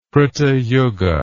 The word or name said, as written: Krita Yuga